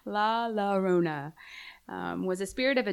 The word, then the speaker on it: Llorona: Well, one of them that I found really interesting, and it was more in the Hispanic community, is La Yo- oh. – Llorona.